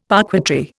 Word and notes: parquetry: Indian

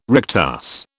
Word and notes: rectus: British English

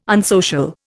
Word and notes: unsocial: Indian

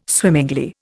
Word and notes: swimmingly: Indian

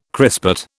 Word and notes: crispate: British English

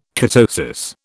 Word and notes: ketosis: British English

